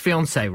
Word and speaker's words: fiancé: Still pronounced fiancé, right, isn't it? – Yeah, as far as I know.